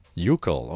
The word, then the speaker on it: Yukel: Uchol. Mm-hmm. How do you spell that? – Y-U-K-E-L. – Uchol.